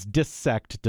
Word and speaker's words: dissect: He says, dissect does not rhyme with bisect. I would discourage you from pronouncing it in the ways that you did.